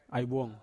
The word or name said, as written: aivuong